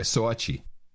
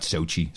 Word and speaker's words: Sochi: Well, that's the way to pronounce it, by the way. Soachi. Soachi. Not Sochi. Soachi.